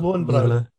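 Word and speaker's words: Löwenbräu: Is it Lobra? Lobra? Lobra? – Lowenbrow. Lowenbrow. – Lowenbrow. – That's the UK version pronunciation. Yeah. It would be Lowenbrow.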